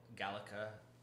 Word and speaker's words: Gallic: So Gallica was originated in Gaul. When Caesar was doing his Gallic conquests. – Gaelic? Yeah, I realize it's actually properly pronounced Gallic.